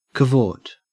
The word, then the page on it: cavort: Australian English